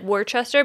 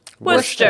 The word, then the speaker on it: Worcester: It's spelled like Worcester, but it's pronounced Worcester.